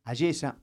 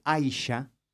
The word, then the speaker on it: Ayesha: Ayesa, como decía, se escribe A-Y-E-S-H-A. Ayesa o Aisha, ¿no?